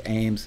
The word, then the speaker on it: Eames: Ames, it's pronounced Ames, but spelled like Eames.